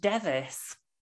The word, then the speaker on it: Device: That surname is pronounced Devis.